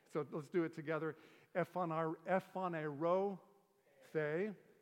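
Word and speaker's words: Ephanerothe (ἐφανερώθη): Each line ends in the sound, the syllable the. It goes like this: Ephanerothe, he appeared.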